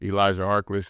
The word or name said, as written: Elijah Harkless